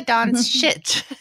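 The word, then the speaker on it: Dončić: Sorry, let me say that correctly. It's Luka Doncic. And I always have trouble with his last name, but it's that Slovenia, I guess.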